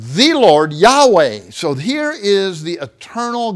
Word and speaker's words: Yahweh: And so in older translations, it was translated as Jehovah, as trying to transliterate the Hebrew into English letters, J-H-V-H. Scholars now think probably a better designation would be, like if we'd spell it, Y-E-H, W-A-H, Yahweh.